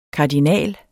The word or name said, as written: kardinal